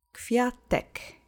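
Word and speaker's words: kwiatek: Accent is on the first syllable. Квятек. Квятек. Квятек.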